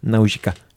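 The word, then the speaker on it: Nausicaä: E, Naujika of the Valley of the Wind. Doğru telaffuz ediyorum değil mi Yavuz? Naujika. – Japonlar evet, böyle evet, telaffuz benim ediyor. Benim bildiğim kadarıyla böyle.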